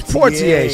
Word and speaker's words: Poitier: It's Poitier. She didn't even say it right.